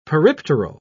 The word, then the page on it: peripteral: British English